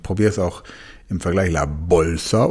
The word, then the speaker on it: la bolsa: Das B im Spanischen, la bolsa, wird nicht wie ein B im Deutschen ausgesprochen, sondern die Lippen berühren sich beim B nicht. Also statt la bolsa, la bolsa.